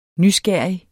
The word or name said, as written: nysgerrig